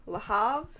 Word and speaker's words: Le Havre: And they are heading from Le Havre. Is that how you pronounce it? – Yeah.